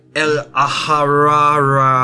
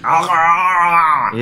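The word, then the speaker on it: El-ahrairah: Yeah, El Aharara. – Aharara. – Yeah, that's it. Wait, hold on a second, that's the name. – El Aharara.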